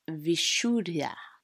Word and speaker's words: Vishuddha: Now, the throat chakra in Sanskrit is pronounced Vishuddha, which translates to pure.